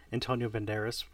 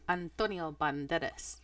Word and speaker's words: Antonio Banderas: I definitely think Antonio Banderas would have been a great... No, it's Antonio Banderas.